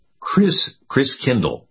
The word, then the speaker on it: Christkindl: And the German pronunciation of Christ child is Christkindl, like kindergarten, kindergarten.